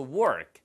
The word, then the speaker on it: work: after work. R 발음 살리셔야 일이란 뜻이고요. 아, walk. 이렇게 길게 발음하시면 걷다 혹은 산책이란 뜻입니다.